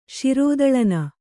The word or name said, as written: ಶಿರೋದಳನ